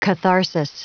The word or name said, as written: catharsis